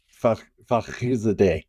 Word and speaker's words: Fakhrizadeh: And the one where Mohsen Fakhrizadeh, which I probably butchered his name again. – Fakhrizadeh.